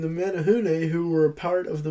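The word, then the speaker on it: Menehune: No, I actually had to look up before the episode how to say this. This is the Menahune. The Menahune.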